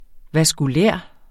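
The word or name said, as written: vaskulære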